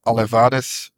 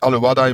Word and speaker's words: Alevades: Hè, toen de Alevades, ik heb het gevraagd aan een van de supposten. Het is niet Alevadai, maar Alevades uitgesproken in het Grieks.